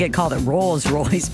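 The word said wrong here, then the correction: Rose Royce: but it's Rose, R-O-S-E, Royce, called Car Wash.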